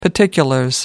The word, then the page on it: particulars: British English